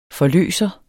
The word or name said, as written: forløser